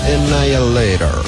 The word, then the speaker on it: Annihilator: Recibí un par de tweets de alguien que decía que yo tendría que haber grabado la microguía porque el locutor dice Annihilator. Que igual es como le dice todo el mundo a la banda. Sí. Pero si pronunciamos correctamente es Annihilator.